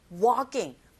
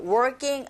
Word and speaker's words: walking: working 아니고 walking. L 사운드는 묵음입니다.